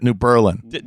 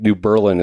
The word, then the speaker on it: New Berlin: Yes, B-E-R-L-I-N, yes. – Is there a... – It's spelled New Berlin, but we all pronounce it New Berlin. – Have presumably since 1918. – That's kind of like a New England type pronunciation, like New Berlin.